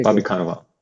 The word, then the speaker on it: Cannavale: Bob Cannavale or what is it? – Yeah, Bobby Cannavale, right? Yeah, okay, Bobby Cannavale.